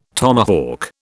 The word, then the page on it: tomahawk: British English